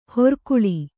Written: ಹಿರಿಕೊಳ್

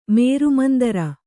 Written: ಮೇರುಮಂದರ

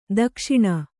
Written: ದಕ್ಷಿಣ

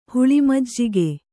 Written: ಹುಳಿಮಜ್ಜಿಗೆ

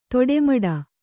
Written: ತಿಟ್ಟಮಿಡು

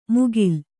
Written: ಮುಗಿಲ್

